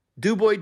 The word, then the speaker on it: Du Bois: I think the S is silent in the first round. Du Bois Dream, 0-3 all time in TBT.